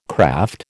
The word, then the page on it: craft: American English